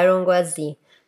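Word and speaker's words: Iron Gwazi: O nome dela é Iron Guazi. Eu acho que é assim que se pronuncia. – Não, é Iron Guazi.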